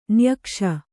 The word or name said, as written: ನಿಯೋಗಿಸು